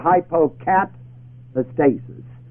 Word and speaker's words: hypocatastasis: Now, a stasis is spelled A-S-T-A-S-I-S. Put the two together, hypocatastasis. Hypocatastasis. H-Y-P-O-C-A-T-A-S-T-A-S-I-S.